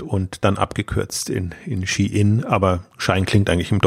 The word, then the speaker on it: Shein: Und fangen wir mit äh, Shine, Shein, Fashion an. Kann man unterschiedlich aussprechen wir, wir, wir, sagen, wir sagen Shine, aber eigentlich das Unternehmen sagt Shein.